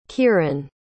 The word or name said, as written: kieran